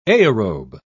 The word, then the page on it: aerobe: British English